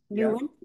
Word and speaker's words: Nouwen: Yes, Nouwen.